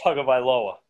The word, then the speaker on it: Tagovailoa: And the freshman quarterback, I, I can't say his name, DJ Ugalele. I don't, I, that's as close as I can get. But with Tagovailoa.